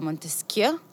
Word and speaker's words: מונטסקיו: יש לנו את המודל של מונטסקיו. נכון? ככה מבטאים את זה? – ככה מבטאים.